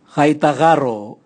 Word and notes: gai tagaro: Raga

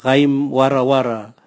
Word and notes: gaimwarawara: Raga

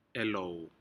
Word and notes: ehlou: Aneityum

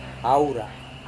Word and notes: haura: Serewen

